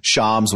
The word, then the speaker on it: Shams: You know, especially since Shams was seen to having been won the offseason.